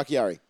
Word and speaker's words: Bakhtiari: Bakhtiari.